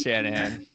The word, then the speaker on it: Shanahan: Yeah, it's Shanahan.